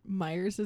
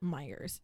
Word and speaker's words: Meijer: For people who don't live in Michigan, Meijer's is a grocery store, and the entire state pronounces it wrong because it's not plural. It's actually just Meijer. But the entirety of the state of Michigan calls it Meijer's, even though there's no S in the name.